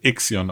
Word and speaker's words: Ixion: äh, es wird Ixion ausgesprochen. – Ixion. Also okay. – sprechen alle von Ixion.